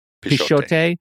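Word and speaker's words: Pixote: I do want to go watch, what'd you say, Pichote? Is that how you say it? – Pichote.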